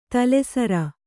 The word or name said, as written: ತಳಸಾರ್